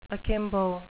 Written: akimbo

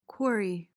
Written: quarry

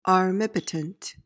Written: armipotent

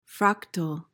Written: fractal

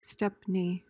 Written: stepney

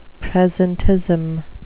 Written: presentism

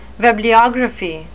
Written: webliography

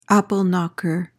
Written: apple knocker

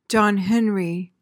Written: john henry

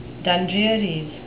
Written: dundrearies

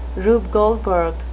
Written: rube goldberg

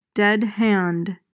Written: dead hand

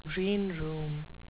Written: greenroom

